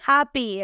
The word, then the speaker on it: happy: No les tengo mucho consejo más que meterle extra fuerza a la P. Happy, happy. No happy, happy, happy. Más fuerza a la P. Labio con labio, explosión. Happy, happy happy.